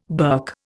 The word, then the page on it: berk: Indian